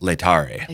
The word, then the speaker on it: Laetare: Laetare.